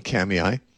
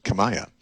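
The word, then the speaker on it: Kamiah: it doesn't look like it should be pronounced Kamiai. It looks like it should be pronounced Kamaya. It's K-A-M-I-A-H. I guez it's a Nez Perce Indian word, and they pronounce it Kamiai.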